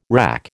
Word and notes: RAC: American English

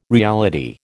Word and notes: reality: American English

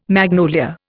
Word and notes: magnolia: Indian

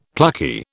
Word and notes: plucky: British English

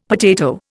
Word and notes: potato: Indian

